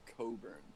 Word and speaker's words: Cockburn: So it's actually pronounced Coburn. – Oh, is it? Oh shit. Yeah. – He told me that because I was like, dude, you're making this shit up. He was like, it's pronounced Coburn.